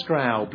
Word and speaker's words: Straub: Straub or Straub, but she said it's ow, just like I punched you in the arm.